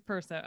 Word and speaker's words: Perso: How do you pronounce your last name? – It is Perso.